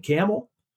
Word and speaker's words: Campbell: it was like a lamb roast in Camel, which is spelled Campbell, but no one says that back home.